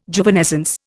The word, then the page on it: Juvenescence: Indian